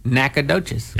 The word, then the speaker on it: Nacogdoches: Nacogdoches. Así es como se dice.